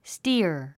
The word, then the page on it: steer: stíə r; スティア